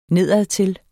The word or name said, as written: nedadtil